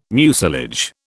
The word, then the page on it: mucilage: British English